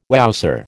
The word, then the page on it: wowser: American English